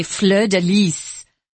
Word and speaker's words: fleur-de-lis: I was mentioning to Tom before we started, what's interesting is that we don't pronounce it the way the French do. Typically, in French, if there's an S at the end of a word, you don't pronounce that S. Oddly enough, on this one, if you're in France, they say fleur de lis.